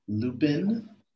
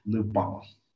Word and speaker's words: Lupin: It's spelled Lupin, but for niggas that be foreign and be knowing shit, it's Lupin.